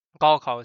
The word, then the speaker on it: Gaokao: So sometimes people will call it the Gaokao. I think that's the English accent. So the Gaokao, Gaokao is the Chinese pronunciation.